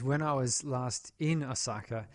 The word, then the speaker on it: Osaka: That there's a state of emergency um, in Osaka. Yes. Did I pronounce that right? Oh, thank God. Uh, you're my, you're the Japanese. Osaka.